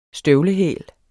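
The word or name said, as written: STØVLEHÆL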